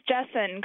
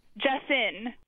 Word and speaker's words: Jessen: This is Jessen calling from Berkeley, California. – Jessa? – Jessen. – Spell it. – J-E-S-S-E-N.